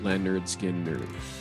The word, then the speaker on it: Lynyrd Skynyrd: Well, the first album is a guide to pronouncing it. Their first album title is pronounced Lynyrd Skynyrd.